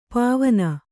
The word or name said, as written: ಪಾವನೆ